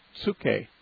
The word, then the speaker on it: psuche: And so when we have in our English translation the word soul in the New Testament, it's from the Greek word psuche, which is has a silent P at the beginning, P-S-U-C-H-E, psuche.